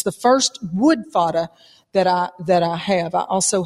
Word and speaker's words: FADA: F-A-D-A?